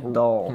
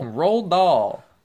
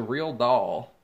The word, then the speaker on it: Roald Dahl: rolled doll. – Roald Dahl. – Rolled doll. – As opposed to real doll.